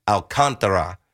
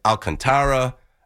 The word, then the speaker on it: Alcantara: I thought when I was on MLB Network, Sandy's last name is pronounced Alcantara with an emphasis on the contra, like that part. But then I hear Alcantara, Alcantara. Alcantara. So that's how I was told to say it.